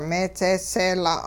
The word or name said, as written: meedzeeseela'